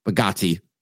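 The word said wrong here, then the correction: Bargatze: I have like a weird, not a phobia of saying names. Like I get stressed when I have to say a name that's just not easy. – Yeah. I've heard it pronounced Bargazzi.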